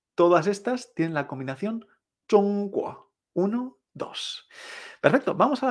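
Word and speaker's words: Zhongguo (中国): La combinación 1-2 es el primer tono, chong, y el segundo, me extraño. La palabra clave va a ser China, que es Zhongguo. Fíjate. Mm, mm. Primer tono mm, y luego me extraño. Zhongguo.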